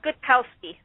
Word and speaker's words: Gutkowski: Is it, well, why don't you pronounce it for me first, and then I won't massacre it. – It's Gutkowski. It's very, very simple, actually.